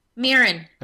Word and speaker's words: mirin: Mirin.